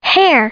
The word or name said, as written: hair